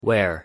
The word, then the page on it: where: English